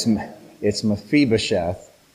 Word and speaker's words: Mephibosheth: It's Mephibosheth. I might pronounce it 8 different ways during the lesson, but I'm pretty sure it's Mephibosheth.